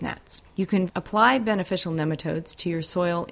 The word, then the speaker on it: nematodes: The best control is to interrupt the life cycle with beneficial nematodes. Nematodes, it's pronounced like nemesis.